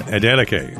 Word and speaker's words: Adeleke: Adeleke? – Adeleke.